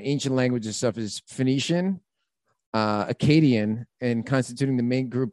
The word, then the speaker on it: Akkadian: Akkadians. – Akkadian is how you pronounce that.